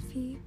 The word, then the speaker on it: Vy: In case you didn't know, my name is V. It spells like V-Y, but you say it like V.